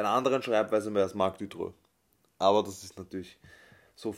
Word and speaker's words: Marc Dutroux: Also falsch ausgesprochen, so wie wir es, glaube ich, immer gesagt haben, ist Marc Dutreux. Marc Dutroux ist richtig.